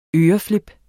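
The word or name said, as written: ØREFLIP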